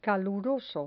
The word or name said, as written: caluroso